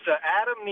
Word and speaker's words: Adam: Well, it's Adam Niemerg, actually. Niemerg. That's close.